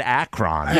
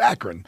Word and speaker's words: Akron: He didn't even know how to pronounce Akron. He kept calling it Akron. – Yeah, that was weird. – Yeah. – Well, some of you East Coast people, they call it Akron. – Is that how you say it? – That's not how you say it. – Okay. – It's Akron.